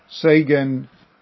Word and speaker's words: Seigen: Seigen is the Japanese way of pronouncing the characters. It's in Chinese, I'll try to pronounce it, it's Qingyuan.